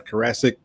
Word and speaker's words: Carasik: We have Mr. Scott Carasik. Carasik, did I say it right? I'm sorry. – You said it right the first time. – There we go. Carasik.